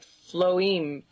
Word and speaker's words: phloem: Banana strings are actually officially called, in scientific terms, phloem, and that's spelled P-H-L-O-E-M. So it sort of reads like phloem, but pronounced phloem.